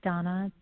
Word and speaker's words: Dana: This is Donna B. My name is spelled D-A-N-A.